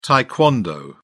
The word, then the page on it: tae kwon do: British English